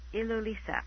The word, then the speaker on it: Ilulissat: Which is in a town called Ilulissat. – Very good. – I have to slow down to say it right.